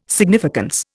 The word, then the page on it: significance: Indian